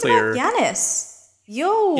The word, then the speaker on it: Giannis: It's pronounced Giannis.